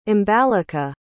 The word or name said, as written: ambalika